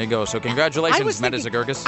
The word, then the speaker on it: Zegergis: First name is M-E-D-D-A, Meta. – Okay. – Last name, Z-E-G-E-R-G-I-S. Zagurgis. – It's not that hard. – No, you're adding another syllable there. There's no A. – Oh. – It's Z-E-G-E-R, like Zegur. – Zegur. – G-I-S.